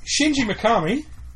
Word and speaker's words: Shinji Mikami: Shinji Mikami has pulled... Did I pronounce that properly? I did.